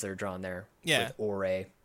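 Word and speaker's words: Ouré: We got Ore. I do not like this name for a game. O-U-R-E is how you spell it.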